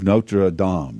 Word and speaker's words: Notre Dame: The Hunchback of Notre Dame, Danny. That's how you pronounce it.